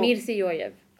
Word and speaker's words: Mirziyoyev: Ja, men Mirziyoyev har jag förstått att det uttalas.